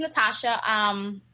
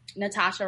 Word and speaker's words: Natacha: Yes, my name is Natacha, Natacha Robert.